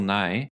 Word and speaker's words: Nighy: Bill Nighy well apparently that's how you pronounce it uh, one of the greatest yes.